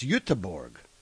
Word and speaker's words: Göteborg: it's sort of like Gothenburg in Sweden, which, Sweden, of course, is not anything like Gothenburg. It's Göteborg. Anyway, pet peeve of mine...